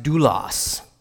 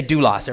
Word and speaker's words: doulos: And that word in the Greek is pronounced doulos. Say doulos, everyone. Doulos. Nice, right?